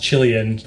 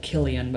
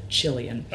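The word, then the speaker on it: Qilian: The mountain range is called, is pronounced Chilean, but we're not talking about Chile. We're talking about China still. – That's exactly right. It's spelled with a Q. So, you know, most Westerners look at it and think that it's called Kilian, but actually the Chinese call it Chilean.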